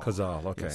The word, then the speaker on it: Kozol: You have used, as I understand it, Jonathan Kozol, I think I'm pronouncing that right. Yeah, Jonathan Kozol. – Kozol, okay.